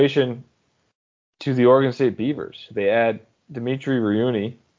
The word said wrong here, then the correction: Rivney: Rivney, I've been told this pronunciation.